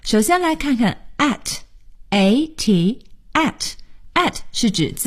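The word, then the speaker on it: @: 有的同学读圈 A 花 A，这都不对。在英文里呢，应该是读成 at，就是我们新学的这个 at 这个字。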